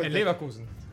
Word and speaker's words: Leverkusen: Ah, el pues, pues, pues, ah, ah, Leverkusen.